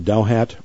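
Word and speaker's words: Douthat: I think his name is pronounced Dauhat, Dauhat.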